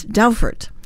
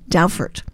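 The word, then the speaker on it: dowfart: It's Dowfert, spelled D as in dog, D-O-W, F as in Frank, A-R-T, D-O-W, F-A-R-T, pronounced Dowfert.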